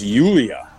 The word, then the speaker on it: Julia: Oh, I met somebody at a little deal yesterday, and her name is Julia, but she went with a soft J. So it's Yulia, which is fascinating.